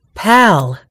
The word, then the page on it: pal: British English